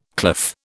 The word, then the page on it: cliff: British English